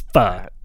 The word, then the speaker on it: pho: Pho? – It's pho. – Pho? – Pho. – Oh, cool. – Yeah, like that. Fun without the N.